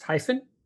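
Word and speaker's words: HIFON: So now that we know what it is, and actually, is it pronounced hyphen? – That's correct.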